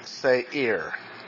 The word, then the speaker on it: Seir: So Esau, or Edom, lived in the hill country of, and this is not Seir. It is pronounced Seir. He lived in Seir, long A, Seir.